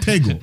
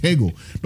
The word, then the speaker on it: Tego: You're a neuroscientist, Dr. Sego. – Yeah. You keep pronouncing it with an S, don't you? – Dr. Sego. – Tego. With a T. – Oh, Dr. Yeah. Tego. Oh, Tego.